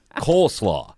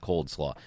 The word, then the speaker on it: coleslaw: Coleslaw. That's how you say it. When I was a kid, I said cold slaw.